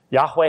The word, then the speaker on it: Yahweh: Yahweh has two syllables.